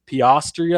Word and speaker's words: Piastri: Oscar, I'm definitely going to butcher his name, but it's Oscar Piastri, I think is how it is.